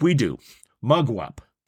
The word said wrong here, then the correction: mugwump: Mugwup. M-U-G. Wup. Hold on. Let's see what it says. I hit. There we go. Oh, I got to turn it up. – Mugwump. – How do you say it? – Mugwump.